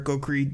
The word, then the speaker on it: Okrie: okrie d-e-r-e-k that's the right way to spell it my last name o-k-r-i-e um, always trying to get on there when I can.